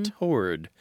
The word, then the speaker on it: toward: Yeah, the pronunciation of T-O-W-A-R-D, the kind of, how shall we put this, classic, maybe even elitist pronunciation is toward, T-O-R-D. But many, many, many, many people throughout the English-speaking world, throughout the Anglosphere, say toward. They say toward. – It toward. They say it like it's written. They pronounce the W. It's two syllables, not one.